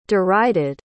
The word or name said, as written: derided